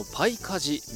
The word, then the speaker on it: 南風: ありますね、もう中には真っ黒になってる子もたくさん出てきて、はい、そもそもですねこのパイカジ、南風と書いてパイカジと読むんですけども、こちらどのようなチームなんでしょうか。